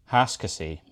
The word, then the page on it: Hascosay: Shaetlan